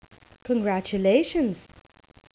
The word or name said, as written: congatulations